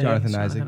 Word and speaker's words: Jonathan Isaac: But I think that limits Siakam a little bit because there's Jonathan Isaac, right? Am I saying that right? – Yeah. – Jonathan Isaac.